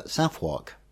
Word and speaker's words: Southwark: And also how Southwark is pronounced in London versus in Philadelphia, where apparently it's Southwark.